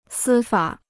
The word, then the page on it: 司法: sī fǎ